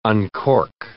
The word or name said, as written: uncork